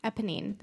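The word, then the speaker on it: Éponine: Emmeline? – Eponine. – That's it. I always want to put an L in there.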